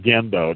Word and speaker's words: Gendo: Well, first of all, it's pronounced Gendo. It's it's not the soft G. It's a hard G. It's a Japanese word, so it's pronounced Gendo. Uh huh.